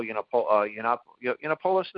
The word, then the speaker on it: Yiannopoulos: Maybe they didn't like the idea of Milo Yiannopoulos, Nathaniel, I'm pronouncing it right.